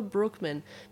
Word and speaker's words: Broekman: You'll find my website on my Instagram if you just type my name in, Taylor Brookman. Maybe they need to know how to spell it. – Brookman. – Brookman. – That doesn't help. – B-R-O-E-K-M-A-N. Dutch spelling.